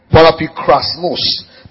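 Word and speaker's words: Parapikrasmos (παραπικρασμός): Parapikrasmos. P-A-R-A-P-I-K-R-A-S-M-O-S. Para means beside. I'll say it again. P-A-R-A-P-I-K-R-A-S-M-O-S.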